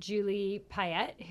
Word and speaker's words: Payette: So my situation where my rent receipts is about Julie Payette, who was just named Governor General. Am I saying her name right? – Yeah.